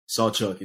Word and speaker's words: Sawchuk: Sawchuk. It's just like it like it's spelled. S A W like the saw and then Chuck. Just don't forget just there's no C after the U. It's just UK.